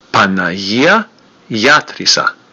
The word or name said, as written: Panayia Yiatrissa